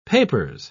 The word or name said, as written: papers